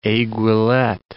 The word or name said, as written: aiguillette